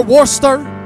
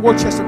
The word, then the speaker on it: Worcester: Jesse from North Carolina or Worcester? They're like, Pastor, it's not Worcester, it's Worcester. They should spell it like that then, I'm saying.